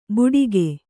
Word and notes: ಬುಡಿಗೆ: buḍige; kannada

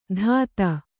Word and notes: ಧಾತ: Kannada

